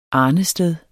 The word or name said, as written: arnested